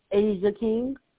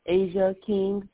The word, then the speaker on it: Asia King: Asia King. – Okay, can you spell that for me, please? – A-S-I-A, Asia King, K-I-N-G.